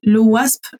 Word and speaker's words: OWASP: et là je ne sais pas comment je le prononce, c'est l'OWASP, est-ce que je le prononce comme il faut? Parfait.